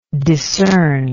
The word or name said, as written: decern